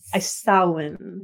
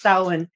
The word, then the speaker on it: Samhain: What is the proper pronunciation? – Samhain. – There we go. – Everybody. Yes, it's Samhain. Yes. It's Samhain. – Yeah. Okay. – I know everyone, because it looks like Samhain, alas, but, and my students are never get it right, but it is indeed Samhain.